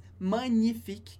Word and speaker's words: magnifique: Palavra que a gente usa bastante em francês, magnifique, com o GN, que sempre tem o som de ny, parecido com o NH do português.